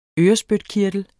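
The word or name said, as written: ØRESPYTKIRTEL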